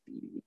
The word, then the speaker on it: BIBI: It's BIBI, and I think it's pronounced BB, but.